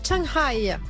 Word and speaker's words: Chiang Rai: Allez, on part en Thaïlande, à Chiang Rai, rejoindre Marine. C'est ainsi que l'on le prononce ? – Oui, c'est comme ça.